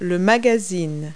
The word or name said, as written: magazine